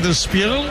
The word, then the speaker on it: Der Spiegel: En he flipado con el arranque y la pronunciación de Der Spiegel, el periódico alemán. He flipado. Spiegel.